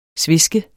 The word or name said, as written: sveske